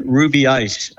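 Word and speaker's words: Rubiães: We are currently in Ruby Ice, I was just told it's pronounced.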